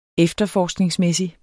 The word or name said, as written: efterforskningsmæssig